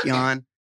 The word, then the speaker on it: Jahn: J-A-H-N is my last name, pronounced Yan in Google. Or if you just search Dynamics, D-Y-N-A-M-I-X, you'll find us.